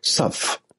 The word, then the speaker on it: Tsuph: and the name of the honey is Supph, T-S-U-P-H. The T is silent.